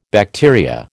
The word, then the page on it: bacteria: American English